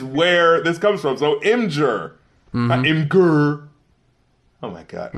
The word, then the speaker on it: Imgur: And I'm Rap Critic, and it's pronounced Imgur.